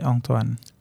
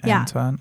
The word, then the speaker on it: Antoine: In French, we kind of say it like in Thai, Antoine.